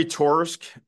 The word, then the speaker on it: Torisk: Jeremy Torisk is my last name is spelled to risk anywhere on social media.